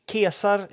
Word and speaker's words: Caesar: Kesar uttalades han eh, på sin gamla tid.